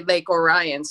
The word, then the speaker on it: Lake Orion: Well, and thank you for saying it correctly. Usually when you're not there, people say Lake Orion. So I'm very impressed that you said Lake Orion the way you did.